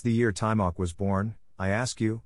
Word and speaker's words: Taimak: His name, pronounced Taimak, was given to him by his father.